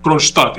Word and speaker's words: Kronstadt: Sí, la ciudad, su nombre es bastante difícil, se llama Kronstadt.